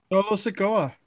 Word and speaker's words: Solo Sikoa: Is it Solo Sikoa? Is that correct? – Yeah, you got it. – Solo Sikoa.